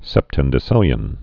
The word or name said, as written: septendecillion